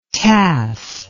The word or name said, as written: tace